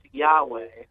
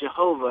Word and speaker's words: Yahweh: Now, I heard you say it's pronounced Yahweh. And yeah, that's the most likely pronunciation.